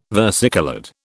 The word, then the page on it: versicoloured: British English